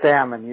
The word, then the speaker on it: Ammon: Yeah, it's Ammon. You said it right.